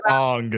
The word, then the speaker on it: Aang: He's called Ong instead of Aang, and that was his reason. He's like, it's actually pronounced Ong.